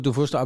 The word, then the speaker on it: Dufourstraße: Dufourstraße, aber das ist ein Franzose, nicht ein Franzose gewesen, sondern ein deutscher, toller Kaufmann. – Ein hugenottischer Einwanderer und spricht sich natürlich französisch aus.